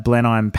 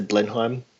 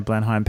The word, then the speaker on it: Blenheim: And it is that the Jacksons are embarking on their 50th anniversary world tour in 2017, including a performance coming up at Blenheim Palace. I think that's how you say it. No, wrong. I think to me I would say Blenheim. – Yeah, Blenheim Palace.